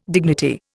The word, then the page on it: dignity: Indian